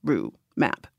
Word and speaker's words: Rue Mapp: And I just feel really solid, like being like a 2-syllable name, Rue Map.